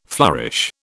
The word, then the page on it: flourish: British English